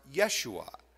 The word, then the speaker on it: Yeshua: The Hebrew name is actually pronounced Yeshua, which if we were to translate it literally would be Joshua.